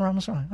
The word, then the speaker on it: Ramaswamy: And that, some of you got annoyed with me when I picked on, I always say his name wrong, Sean, Ramaswamy. It's not Ramaswamy, right? What? Oh, I said it right? Victor Ramaswamy.